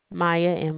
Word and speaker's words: Mya: Maya, MYA.